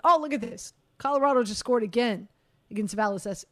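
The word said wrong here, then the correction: Vasilevskiy: I can never pronounce his name Vasilevsky. – Properly. There you go. Vasilevsky.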